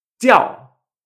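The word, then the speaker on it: jiào: Cuando sonríes, ti, ti, Por ejemplo, tenemos una palabra que se usa con J, que es J-I-A-O en cuarto tono. Esto se pronuncia tiao, tiao, con cuarto tono.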